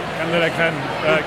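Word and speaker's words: crème de la crème: It's really the creme de la creme. Did I pronounce that correctly? C'est la creme de la creme. – Creme de la creme.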